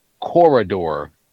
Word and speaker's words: corridor: So you know, Marty, you really when you say corridor, you just sort of skip an entire like syllable. It's corridor.